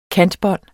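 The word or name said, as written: KANTEBÅND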